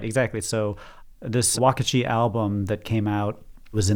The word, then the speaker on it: Uakti: the Brazilian group Wakati, which, by the way, is spelled U-A-K-T-I, and Third Coast Percussion?